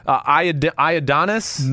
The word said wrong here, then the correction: Ioannidis: Matt Ioannidis.